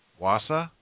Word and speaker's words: Wausau: How do you say that? Wausau? – It's Wausau. – Wausau? – Yeah, Wausau, Wisconsin.